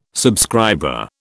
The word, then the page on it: subscriber: British English